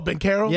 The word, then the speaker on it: Banchero: Banchero, but it's pronounced with a K. Did you know? – Oh, Banchero.